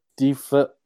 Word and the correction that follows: DeFilippo: Help him out, JR. JR, help him out. – Oh, I say it wrong. – Just call him JDF. JDF. – Yeah. JDF. – DeFilippo. – DeFilippo, there it is.